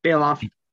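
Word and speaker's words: Baloff: Now, this is the only studio album to feature Paul Bailoff on vocals. Hope I said that right. – Yeah, Bailoff.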